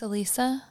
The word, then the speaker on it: Elisa: Eliza Lamb. – Yeah. Her name's Elisa. – Elisa Lamb, sorry.